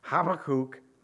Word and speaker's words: Habakkuk: Centuries later, Habakkuk, and that's how you say it, believe it or not, Habakkuk, on the eve of national disaster, his country was going through, or going about to go through, a national disaster.